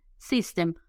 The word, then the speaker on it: system: La palabra número 5 es la palabra sistema, bastante fácil, system. Ocurre lo mismo que les dije sobre la Y. System se escribe con Y, S-Y-S-T-E-M, pero en inglés las Y, muchachos, se pronuncian como Y latinas, ¿ok?